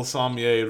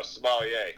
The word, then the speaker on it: sommelier: Yeah, we'll also need a little sommelier advice. If anybody's down for that. – It's French. – Is that, am I saying it right? – No, sommelier. – Sommelier. A sommelier.